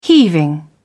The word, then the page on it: heaving: British English